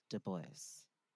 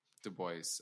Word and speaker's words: Du Bois: Du Bois. – Du Bois.